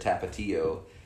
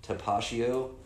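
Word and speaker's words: Tapatío: But she tried to correct them when they said tapatio by saying, actually, you guys, it's pronounced tapatio. It's Mexican. It's not French.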